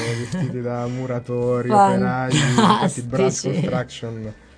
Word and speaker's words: Brass Construction: Brass Construction, yeah. – La pronuncia è andata bene. – Perfetta, dopo ti dovrò raccontare queste versioni.